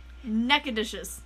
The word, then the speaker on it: Nacogdoches: Another thing found in the car was a prescription bottle, which led agents into a drugstore in, sorry for butchering this, Texans, Nacogdoches? – Nacogdoches. – That sounds like it.